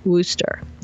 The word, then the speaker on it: Worcester: Was how you spell, you pronounce Worcester, which is spelt W-R-C-H-E-S-T-R, like Worcester, but we say it W-O-O-S-T-E-R, like rooster, Worcester.